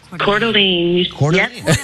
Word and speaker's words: Coeur d'Alene: Coeur d'Alene.